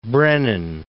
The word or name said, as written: Brennan